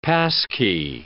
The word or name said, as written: passkey